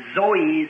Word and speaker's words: Zoe: And the, both Greek words, as the life of God or eternal life, is pronounced Zoe, Zoe, Zoe life. – Y las dos palabras griegas y la vida de Dios o vida eterna se pronuncia Zoe, Z-O-E, Zoe, vida.